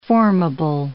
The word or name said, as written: formable